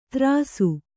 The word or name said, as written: ತರಾಸು